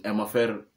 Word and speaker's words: MFR: Um, Amafer MFR Souls. Yeah, that's the right pronunciation.